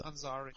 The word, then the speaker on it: Ansari: Oder Ansari, wie wird er ausgesprochen, weiß ich gar nicht. – Ansari, Auf jeden Fall Ansari. Ansari.